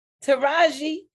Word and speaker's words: Taraji: Not Taji. What's, how you pronounce her name? – Taraji. Taraji. – Taraji.